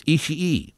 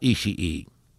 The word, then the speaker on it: Ishii: It's Ishii. I asked that exact question in Long Beach. It's not Ishii. That's what I was told. By guess who? Ishii.